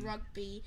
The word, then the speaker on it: rugby: There are 15 Possetins in Regbi. There are 15 Possetins in... – Positions. – I can't say that you said it correctly. – There are 15 positions in rugby.